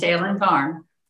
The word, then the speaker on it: Salen Farm: Am I pronouncing this right? Salen Farm Modern? – Yes. Salen Farm.